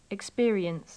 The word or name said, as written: experience